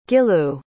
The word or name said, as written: gilou